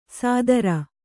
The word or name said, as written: ಸಾದರ